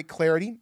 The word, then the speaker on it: Clardy: You can follow me, Troy Clarity, on Twitter. At Troy Clarity is the way to go there. At Troy Clarity, last name is spelled C-L-A-R-D-Y.